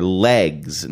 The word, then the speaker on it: L'eggs: This is an old ad from the 1980s for Legs. Now, that's spelled L'EGGS, Legs brand pantyhose.